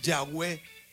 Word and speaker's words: Yahweh: Ah, YHWH. No se puede pronunciar. Uh-uh. Por eso le empezaron a decirle Yahweh. – Ok. – Correcto, se tuvo que agregar las para poder pronunciarlo.